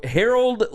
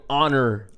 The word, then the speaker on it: Harold: Vince Young called him Honor Landry. – Honor Landry. – There's no If you just enunciate it. If you just, like, phonetically sound it out, you'll say it right. – Like, even if it was Harold. Yeah. Like, Harold. That'd be fine. – Yeah. – But Honor is not fine.